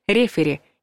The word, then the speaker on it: рефери: Кстати, ударение ставится на первый слог «рефери», несмотря на оригинальное произношение.